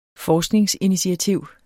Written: forskningsinitiativ

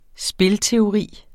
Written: spilteori

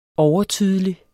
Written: overtydelig

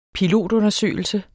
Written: pilotundersøgelse

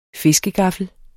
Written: fiskegaffel

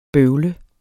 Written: bøvlet

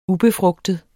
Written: ubefrugtet

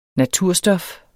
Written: naturstof

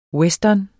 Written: western

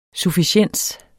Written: sufficiens

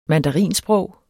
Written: mandarinsprog

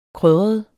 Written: krydret

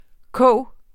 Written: koge

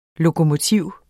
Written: lokomotiv

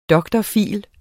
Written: dr.phil.

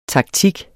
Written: taktik

